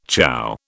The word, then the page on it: ciao: British English